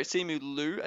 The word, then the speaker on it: Simu Liu: Oh, Simu Lee. He's also in it. Oh, sorry, Simu Liu, I think it's pronounced.